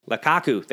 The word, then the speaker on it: Lukaku: La Cucu. Lukaku. That's his name. Lukaku.